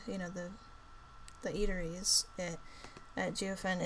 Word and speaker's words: Jiufen: was a town in Taiwan called Jiufen. Jiufen, I don't know if I'm saying it right. It's Jiufen.